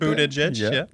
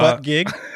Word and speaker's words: Buttigieg: Buttigieg, there we go. Not butt gig.